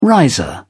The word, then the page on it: riser: British English